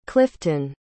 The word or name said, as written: clyffton